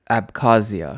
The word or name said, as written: Abkhazia